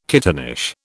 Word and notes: kittenish: British English

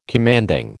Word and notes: commanding: American English

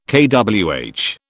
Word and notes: kWh: British English

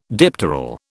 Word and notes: dipteral: British English

